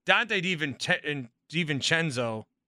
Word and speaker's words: DiVincenzo: Dante DiVincenzo is how you say it.